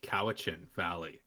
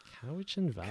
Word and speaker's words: Cowichan Valley: Oh, Cowichan Valley. – Cowichan Valley.